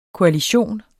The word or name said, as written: koalition